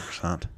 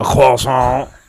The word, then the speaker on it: croissant: She said, can I have a croissant? And I said, oh, honey, it's pronounced a croissant. I said, you actually can't have one until you order it correctly.